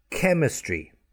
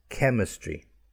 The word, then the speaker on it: chemistry: It is spelt O-R-C-H. Here's the ch, E-S-T-R-A. But the sound is k, orchestra. Cancel, C-A-N-C-E-E-L. Okay, chemistry, K, chemistry, C-H-E-M-I-S-T-R-Y, K, chemistry. Okay, so we've got that, again, that sound with the C-H.